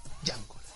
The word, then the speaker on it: Django: Django. La D no se pronuncia.